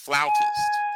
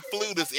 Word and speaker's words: flautist: So, by the way, Bradley, it is pronounced flautist. – A flautist? – Yes. – What? – You're a flautist. – It's not flutist. It is actually pronounced flautist.